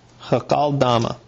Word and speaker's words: Hakeldama: And it became known to all who were living in Jerusalem, so that in their own language, that field was called," how would you say that? Hakeldama? Yeah, it's Aramaic. It's Hakaldama. Yeah, Hakaldama. That is the field of blood.